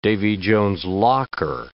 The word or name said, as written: Davy Jones's locker